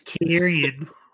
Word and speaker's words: Karrion: It's Karrion.